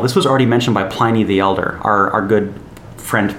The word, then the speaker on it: Pliny: So Pliny the Elder is how it's pronounced.